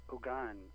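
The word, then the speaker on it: houngan: In Haitian Creole, it would be Ogan. It's H-O-U-N-G-A-N. And the H is silent.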